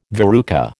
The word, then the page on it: verruca: American English